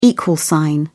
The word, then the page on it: equal sign: British English